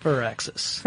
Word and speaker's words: Firaxis: Yeah, but it's pronounced Firaxis. Like fir tree.